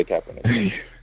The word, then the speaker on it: Kaepernick: I think that's how you say his name. – Yeah, Kaepernick.